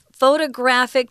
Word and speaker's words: photographic: So it is a primitive photographic technology. Now, photographic um, actually has the in on the third syllable.